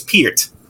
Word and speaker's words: Peart: By the way, it's Peart. pronounced Peart. – Peart. You're correct. You're correct. I say Peart.